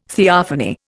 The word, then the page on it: theophany: Indian